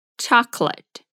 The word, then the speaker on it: chocolate: Mas olha só a pronúncia de chocolate em inglês: é chocolate chocolate.